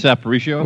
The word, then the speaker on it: Aparicio: And Luis Apprecier, I can't even say his name. Lu, Luis Luis Aparicio.